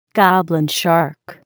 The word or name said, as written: goblin shark